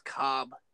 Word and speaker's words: Coub: I thought it was Coob because it was like C-O-U-B. – Is that Coob? Coob? – I thought it was Coob, or but like they made a video about it and it's actually pronounced Cobb.